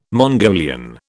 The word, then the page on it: Mongolian: British English